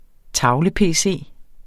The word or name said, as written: tavle-pc